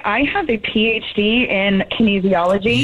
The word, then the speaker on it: kinesiology: So, no, you stupid idiot. It's pronounced kinesiology.